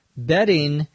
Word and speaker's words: betting: There's a word that's pronounced in most cases almost exactly the same, in fact, exactly the same, which is bedding, B-E-T-T-I-N-G. In English, in normal conversation, the D and the T, if they are in between two vowels in a word, are pronounced the same. Although, if someone doesn't quite understand you, they may overpronounce it or hyperpronounce it. They may say bedding versus betting.